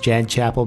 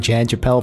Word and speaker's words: Jan Chapel: Questions, comments, concerns, angry rants, love letters, your thoughts on not Callie / the High Priestess / Jan Chapel's acting, as we've just found out it's actually pronounced Jan Chapel, because RG was like, it's pronounced Jan Chapel before we started recording, even though we've been saying Jan Chappelle for the past, like... half a year.